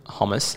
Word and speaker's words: hummus: Nah, Dan and I have been making falafel wraps with hummus. How do you pronounce it? – Hummus. – Hummus. Cool.